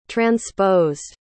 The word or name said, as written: transposed